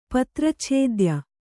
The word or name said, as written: ಪತ್ರಚ್ಛೇದ್ಯ